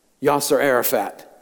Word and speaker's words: Yasser Arafat: The talks eventually broke down, and so the leader of the Palestinian Authority, Yasser Arafat, how do we pronounce his name? Yasser Arafat.